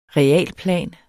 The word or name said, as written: realplan